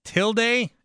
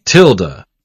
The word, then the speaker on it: tilde: A couple of people, including Dan here, tweeting me, Tilde? It's a Tilde? – Tilda.